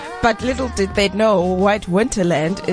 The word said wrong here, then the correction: White Hinterland: See, within 2 minutes, you've already got a name wrong. It's White Hinterland.